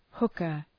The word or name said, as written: hooker